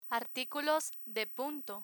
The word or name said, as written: articulos de punto